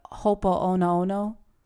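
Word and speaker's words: Ho'oponopono (Hoʻoponopono): I always mispronounce this name, but Ho'oponopono, is that what it is? – Yeah.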